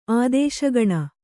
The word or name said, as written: ಆದೇಶಗಣ